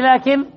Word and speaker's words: لكن: الحرف الرابع فيه لكن الألف الذي بعد اللام. الألف الذي بعد اللام اصطلح العلماء أنها تنطق ولا تكتب. الألف في لكن ولكن الذي بعد اللام اصطلحوا أنها لا تكتب لكنها إيش؟ تنطق وتعد.